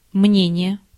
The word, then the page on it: мнение: /ˈmnʲenʲɪjə/